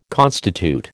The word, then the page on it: constitute: American English